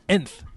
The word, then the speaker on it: nth: Nth metal is actually pronounced nth metal.